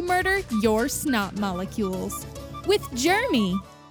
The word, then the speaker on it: Germy: It's spelled Germ Y, like other popular brands, but we prefer to say Germy.